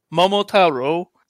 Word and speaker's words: Momotaro: Momotaro. – Yes. – I don't know how it's pronounced. It's probably completely different. – No, it was a very good pronunciation, so well done.